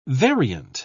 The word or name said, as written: variant